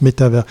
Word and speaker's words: métavers: Donc maintenant, notez bien que ça se prononce métavers.